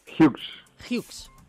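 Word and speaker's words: Hughs: ¿Cómo se pronuncia exactamente? – Hughes, venga.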